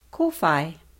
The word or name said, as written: kowhai